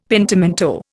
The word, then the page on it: pentimento: Indian